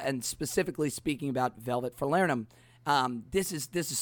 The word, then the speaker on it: falernum: Uh, I was looking up some of the pronunciation guides because I have always said falernum, right? But apparently, uh, the Barbadians pronounce it falernum, falernum, and uh, that has something to do with the um, uh, accent in Barbados and how they actually pronounce it um, with their kind of Caribbean accent. There's a, also apparently a, a fun joke uh, of the origins of this drink.